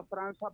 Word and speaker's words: França: Se escribe Franca, pero se pronuncia Franza porque tiene C y C.